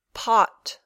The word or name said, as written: pot